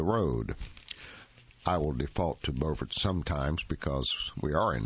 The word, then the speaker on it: Beaufort: The name is spelled, but the same, but pronounced differently, depending on whether you are in North or South Carolina. Writers Seeley and Lynn Selden's article, Battling Beauforts or Beauforts, explains how these two coastal communities share more than a name.